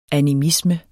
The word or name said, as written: animisme